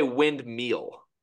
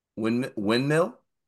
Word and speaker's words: windmill: Can we correct your pronunciation of the word windmill? – Windmill? Because I don't say the D. – You say windmeal. – Windmill? – Windmill.